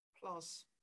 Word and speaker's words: PLOS: PLOS.